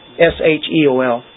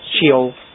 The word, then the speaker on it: Sheol: S H E O L. – Yeah. How do you pronounce that? – Sheol. – Sheol. – Yeah. – Oh, so I did pronounce it right.